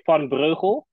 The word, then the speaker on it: van Breugel: I would say in Dutch I would say van Breugel.